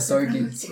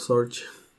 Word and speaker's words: Sorge: No, es que se pronuncia Sorgi. – ¿Ah, sí? – Sorgi. – Yo siempre digo Bob Sorge.